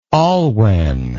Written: olwen

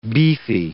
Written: beefy

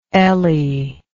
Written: Elli

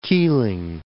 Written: Keeling Islands

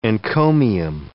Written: encomium

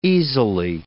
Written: easily

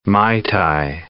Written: mai tai

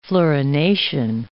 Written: fluorination

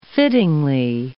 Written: fittingly

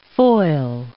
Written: foil

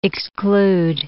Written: exclude

